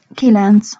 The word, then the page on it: kilenc: Hungarian